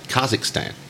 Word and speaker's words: Kazakhstan: And you said Kazakhstan.